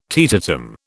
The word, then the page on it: teetotum: British English